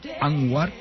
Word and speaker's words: Anwar: Tenemos con nosotros a Zoe, no sé cuál es tu apellido, sé que se escribe Anwar. – Anwar, sí. – Ah, lo he dicho bien.